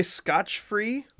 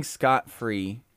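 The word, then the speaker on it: scot-free: F-R-E-E. Scott Free. – I'm not sure if I've ever heard it differently. – Some people say Scotch Free, which I found online as the most common mispronunciation was Scotch Free. – I think Scott Free is right.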